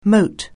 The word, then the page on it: moat: anglais